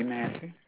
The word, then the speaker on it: Massey: Okay, my name is Betty Massey. – Can you spell that for me, please? – B-E-T-T-Y. – C-A-T-T-Y. – M-A-S-S-E-Y.